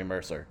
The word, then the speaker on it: Mercier: I think it's just Jeremy Mercer, to be honest. – Oh, okay. – But it's spelled Mercier. – Is it like Tony Parker? – Yeah, so I just pretend like he's French.